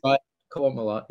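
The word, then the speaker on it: Qowat Milat: That Elnor is, not only is he a Romulan, but he's part of a special sect called, I'm going to get wrong, the Kuat Malat, if I'm pronouncing it correctly. – Kuat Malat, yeah.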